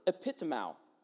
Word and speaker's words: epitimao: And so when we look back at the Greek word that's used here, the word is epitimao, right? Epitimao, E-P-I-T-I-M-A-O would be how we would spell it in English.